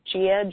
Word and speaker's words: Gia: My name is Gia George, G-I-A, and George, spelled like the first name.